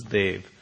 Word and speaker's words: Dev: And I should just point out, when I say Dave, it's actually D-E-V. People hear that and they hear Dave. And of course, that's not an Indian name, so it's D-E-V. But it's pronounced very similarly, though not quite the same as Dave. So it's Dave.